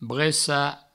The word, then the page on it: brecar: bressa